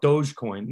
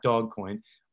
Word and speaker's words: Dogecoin: So we've heard a lot of the news about Bitcoin, or I'm going to probably mispronounce it, but I think I call it Dogecoin, not Dogcoin. But cryptocurrency really making its waves.